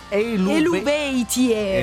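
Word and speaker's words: Eluveitie: Bellissima vocale, la E, anche molto apprezzata dagli Elvezie, che si scrive Elubeitie. – Esatto, esatto, che ho pronunciato male dalle medie, ma poi mi sono aggiornato perché in realtà è in gallico, loro in cantano in gallico.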